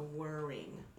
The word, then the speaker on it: whirring: W-H-I-R-R-I-N-G. – Isn't that sad? I can spell it. Whirring. – Mm-hmm. – Oh, so it sounds just like worrying.